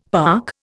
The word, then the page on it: park: Indian